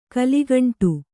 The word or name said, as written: ಕಲುಗುಂಡು